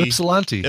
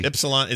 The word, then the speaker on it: Ypsilanti: We got one from Adam from Ysplante, Michigan, or how do you say it? YP. – Oh, Ypsilanti. – Ypsilanti.